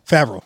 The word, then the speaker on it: Favreau: Favreau. – Favreau. There it is. – Is it really? – Yep, you said it. You got it just right.